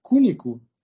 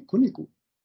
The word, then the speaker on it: Cunico: Então a Camila Cunico, pronunciei bem? Cunico.